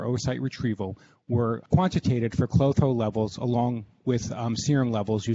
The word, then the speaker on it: Klotho: I'd like to present an article entitled Low, K-L-O-T-H-O, which I'm going to pronounce Clotho, levels relate to aging is associated with diminished ovarian reserve by Dr. Zi and Zhao from Beijing, China.